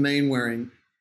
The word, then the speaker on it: Mainwaring: So I say Mainwaring here because that's how it's spelled. It's a crazy name. You know, when you move to another country and they're always like, what's your name and how do you pronounce it? At Simon Mainwaring, which is MainWaring. Australian, what can I say? We say things funny.